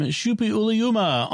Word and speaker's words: Suppiluliuma: Our next feedback comes from Suppiluliuma on Discord. Who, and I want to congratulate on saying saying the name correctly.